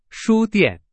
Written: 书店

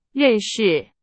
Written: 认识

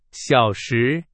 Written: 小时

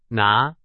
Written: 拿

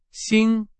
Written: 新

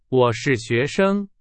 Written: 我

